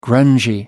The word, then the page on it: grungy: American English